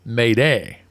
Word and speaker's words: Mayday: Because this comes from the French word m'aides, meaning help me, and is pronounced approximately Mayday.